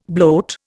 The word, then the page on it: bloat: Indian